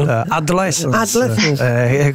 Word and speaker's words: Adolescence: Questa è la prima volta l'hai detto con l'accento giusto. – Adolescence.